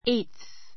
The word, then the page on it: eighth: éitθ; エイトす